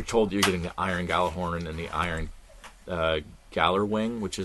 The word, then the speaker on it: Gjallarhorn: It's going to be bringing back some of the old stuff, including Gjallarhorn. I tried to pronunciate the J too much. Gjallarhorn.